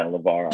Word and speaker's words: Luvara: You nailed it, yeah, Luvara.